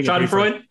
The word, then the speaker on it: Schadenfreude: How do you pronounce it? I am having Schadenfreude. – Yeah, Schadenfreude, whatever it is, from just absolute hubris from Mario Cristobal.